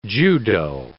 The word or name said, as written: judo